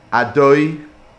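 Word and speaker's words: Adoy (אדני): We say Adoy, which is really written out Aleph Dalet Nun Yud. That's how we pronounce the shame Havaya.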